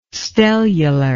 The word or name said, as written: stellular